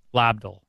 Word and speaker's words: Lobdell: How do you spell that? – L-O-B-D-E-L-L. Lubdol. – L-O-B? – Lubdol. – Would be lob. – Lobdol. – Lobdol. – How about Lobdol? – I'll go with that one.